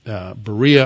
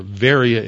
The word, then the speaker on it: Berea: We pronounce it in English Berea or Varia in the Greek.